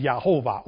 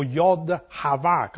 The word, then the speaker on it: Yehovah: The Yod Heh Vav Heh means pronounced Yehovah, or Yod Hava, Kabbalistically speaking. Yod Hava. That's the right pronunciation.